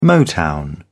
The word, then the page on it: motown: British English